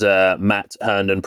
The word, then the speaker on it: Matt Hearnden: So my name is Matt Hearnden, pronounced correctly, as you said the first time, smashed it.